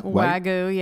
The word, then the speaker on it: Wagyu: Wagyu, yeah.